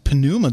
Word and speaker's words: pneuma: That's pneuma. That's a feminine noun. – Pneuma. You don't pronounce the pi.